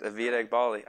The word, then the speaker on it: Avid Eghbali: Avid Eghbali.